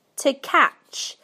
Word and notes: to catch: British English

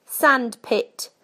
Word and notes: sandpit: British English

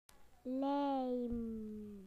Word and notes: glue: British English

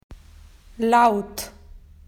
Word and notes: loud: Australian English

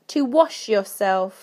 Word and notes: to wash yourself: British English

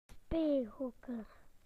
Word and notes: play areas: British English

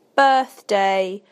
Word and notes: birthday: British English

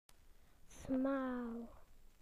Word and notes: narrow: British English

